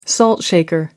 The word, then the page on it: relay race: British English